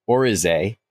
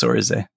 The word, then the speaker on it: oryzae: That's how I've always heard it pronounced, actually. Aspergillus Oryzae. – Okay. – Yep. – I've heard some people say Oryzae, and I don't know which one is correct, but I'm going to stick with Oryzae for now until I'm shown that I'm wrong, and then I will cease and desist.